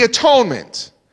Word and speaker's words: atonement: The Puritans used to pronounce this word as the at-one-ment. Because it's at that time that God and man was at one.